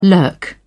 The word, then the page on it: lurk: British English